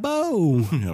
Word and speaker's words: Labo: Labo.